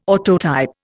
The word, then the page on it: autotype: Indian